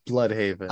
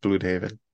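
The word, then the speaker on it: Blüdhaven: I know it's an umlaut. Okay, like I'm not so much of a barbarian that I can't recognize it. But might I offer the fact that everyone else pronounces it as Blood, and like all of the names around Bloodhaven are puns around blood.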